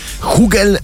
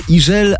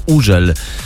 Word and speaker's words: Hugel: Hugel, Hugel, Hugel. No więc tak jest właśnie nieprawidłowo, a za to prawidłowo jest Iżel albo Użel, bo on jest Francuzem i we francuskim to H jest nieme.